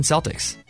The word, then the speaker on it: Celtic: That's Celtic, like the Boston Celtics. – It's Celtic.